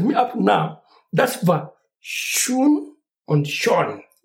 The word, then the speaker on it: schön: So I learned how to pronounce Schön and Schön because of the kid. The kid told me that, Otako, yeah, don't mind. This is how you say it, mm-hmm.